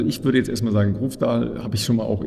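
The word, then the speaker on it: Grøvdal: Es wird auch ein sehr spannendes Debüt geben, nämlich mit von einer norwegischen Athletin, nämlich Caroline, ich glaube, Grofdal spricht man so aus, richtig? – Grofdal, ja.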